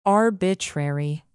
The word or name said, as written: arbitrary